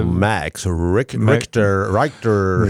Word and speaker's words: Max Richter: Also Max Richter. – Nee, ich glaube, der spricht sich schon richtig aus.